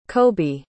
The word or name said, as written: kobe